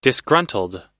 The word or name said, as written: disgruntled